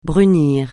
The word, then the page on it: brunir: francais